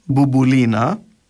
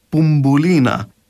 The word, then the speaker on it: Μπουμπουλίνα: Στην κοινή μια χαρά μπορούν να πούν μπουμπουλίνα, ενώ εμείς λαλούμε πουμπουλίνα, δηλαδή κάνουμε το πρώτο μπου να προφερθεί που και το δεύτερο μπου προφέρουμε το μπου.